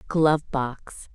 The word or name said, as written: glove box